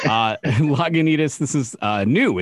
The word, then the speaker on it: Lagunitas: They're called Lagunitas. Did I say that right? – Yeah. I, I believe you pronounced it correctly.